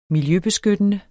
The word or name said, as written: miljøbeskyttende